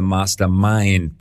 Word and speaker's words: Master Mind: Das zehnte Kapitel heißt Power of the Master Mind, Betonung Mind, ja.